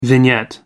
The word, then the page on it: vignette: American English